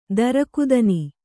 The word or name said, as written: ತಿರುಕತನ